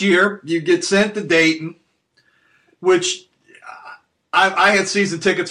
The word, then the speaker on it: Dayton: Tell us about the experience of playing in Dayton.